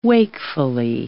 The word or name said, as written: wakefully